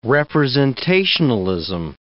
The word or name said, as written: representationalism